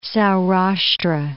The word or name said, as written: Saurashtra